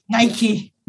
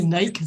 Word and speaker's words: Nike: Nike. We don't say Nike. It's Nike.